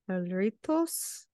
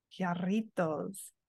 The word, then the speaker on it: Jarritos: It's a Jarritos. Jarritos. – Oh. Jarritos. No. Do you pronounce the J or is it silent? – It's Spanish, so it's a Jarrito. It's more of an H sound.